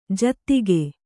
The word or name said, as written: ಜೊತ್ತಗೆ